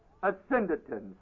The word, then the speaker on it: asyndeton: The one figure is called asyndeton, spelled A-S-Y-N-D-E-T-O-N. Asyndeton, A-S-Y-N-D-E-T-O-N. The other one is spelled polysyndeton, with a P-O-L-Y in front of it.